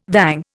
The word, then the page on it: vang: Indian